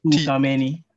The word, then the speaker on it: Tchouaméni: Can't really pronounce the name here, but Tchouaméni.